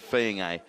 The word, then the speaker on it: Fiennes: and he said Fiennes, so we'll stick with that.